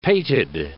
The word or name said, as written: pated